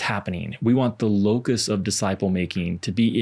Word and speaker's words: locus: So locus is not like the bug. But it's spelled the same. – You hear it buzz. – You don't have a T at the end.